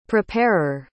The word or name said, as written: preparer